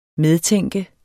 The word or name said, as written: medtænke